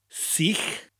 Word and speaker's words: sych: The word for dry is sych. Sych. It sounds like I'm saying the word see in English with a sound at the end of it.